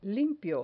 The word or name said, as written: limpio